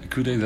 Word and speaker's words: Ikute: I'm going to butcher the pronunciation. Ikute? – Yeah, Ikute, that's good enough.